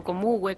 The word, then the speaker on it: Comahue: Así es, porque bueno, ustedes saben que la Universidad Nacional del Comahue, o Comugue, como también lo pronuncian,